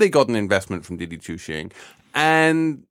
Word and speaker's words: Didi Chuxing: I mean, Uber sold off to I can't pronounce Didi Chuxing. – Didi Chuxing.